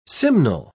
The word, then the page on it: simnel: British English